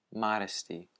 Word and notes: modesty: American English

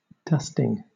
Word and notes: dusting: British English

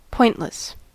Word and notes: pointless: American English

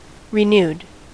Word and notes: renewed: American English

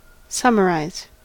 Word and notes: summarize: American English

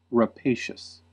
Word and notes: rapacious: American English

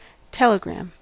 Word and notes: telegram: American English

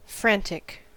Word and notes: frantic: American English

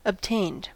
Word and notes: obtained: American English

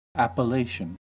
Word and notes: appellation: American English

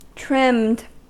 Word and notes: trimmed: American English

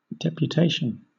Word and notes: deputation: British English

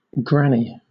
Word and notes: granny: British English

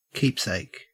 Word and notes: keepsake: Australian English

